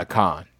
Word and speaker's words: Khan: Yeah, Khan.